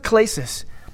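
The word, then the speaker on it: klesis (κλῆσις): It's pronounced klesis.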